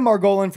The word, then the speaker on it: Margolin: Okay, Ryan Margolin from Pro Hair Labs.